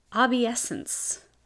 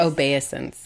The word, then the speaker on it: obeisance: obeisance. Uh, is that the right pronunciation? – Obeisance. – Obeisance.